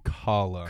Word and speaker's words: Kahala: My truck tells me it's pronounced Kahala.